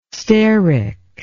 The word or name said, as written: steric